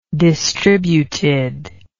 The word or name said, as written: distributed